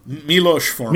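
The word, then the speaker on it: Miloš Forman: Well, who's the director? Milo Forman? – Milos Forman. – Milos. – Yeah, yeah, that's close.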